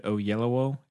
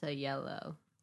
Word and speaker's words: Oyelowo: Yeah, it stars Zac Efron, Matthew McConaughey, Nicole Kidman, John Cusack, David Oyelowo. Is that how you say it? – No. – Sorry, everybody. And... – I think it's Oyelowo.